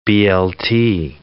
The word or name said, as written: BLT